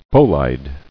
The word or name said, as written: Bolide